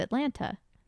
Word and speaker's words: Atlanta: And you went, Atlanta. – I just pronounced both of the T's Atlanta.